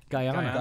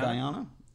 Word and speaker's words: Guyana: Guyana? – Guyana. – Guyana. I always want to overpronounce the U, right? Or the A.